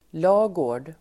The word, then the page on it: lagård: [l'a:gå:r_d]